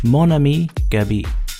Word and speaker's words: Mon Ami Gabi: You know, I gotta tell you, I actually looked up and watched videos on how to pronounce that. Mon Ami Gabi. That's it. That's how it's done.